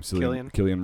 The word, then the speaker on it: Cillian: Cillian Murphy.